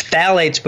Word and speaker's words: phthalates: It's phthalates. So it's phthalates with a PH. So it's the PH is silent.